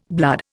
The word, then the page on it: blood: Indian